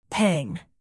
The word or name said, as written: pang